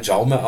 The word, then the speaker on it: Jaume: spricht man das im Original Jaume aus, aber die alten Castellanos sagen dann natürlich Raume und demnach ist es, glaube ich, auch in Ordnung.